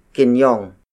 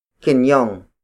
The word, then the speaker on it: kinyong: That would be kinyong. Kinyong. Kinyong. Kinyong is spelled as K-I-N-Y-O-N-G.